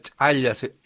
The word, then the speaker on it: chaya: Sí, y la chaya, bueno, también se produce, la chaya, es así como se pronuncia, sí, en quechua.